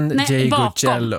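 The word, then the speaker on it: Ndegeocello: Ngeyoye. – Michelle. – Michelle Ngeyoye. – Nej, Ngeyocello. Ngeyocello, ja. Kanske.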